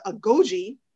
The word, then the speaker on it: Agojie: So there's some controversy around this movie because the, the their group, Nanisca's fighters, the Agojie. Am I saying it right? – Yes, yes, you're saying it.